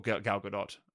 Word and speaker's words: Gal Gadot: or Gal Gadot, I think is the actual way to pronounce it.